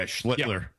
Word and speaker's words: Schlittler: I've seen probably Skeens pitch twice on TV, and I've seen, how you say his name, Schlittler? – Yeah, Schlittler. Yeah, yeah, I've one.